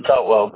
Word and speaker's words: Boutwell: Boutwell, B-O-U-T-W-E-L-L. – B-O-U-T-W-E-L-L, Boutwell, correct? – Boutwell, yeah. – But you said it's B-O-U-T, right? W-E-L-L. Okay. – Yeah. – Okay, you pronounce it Boutwell.